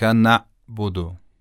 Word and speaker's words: نعبد: تلفظ غلطش رو اول انجام میدم، بعد تلفظ صحیحش رو. ایاک نعبدو، این غلطه. و اما ایاک نعبدو، ایاک نعبدو، این صحیح و درسته.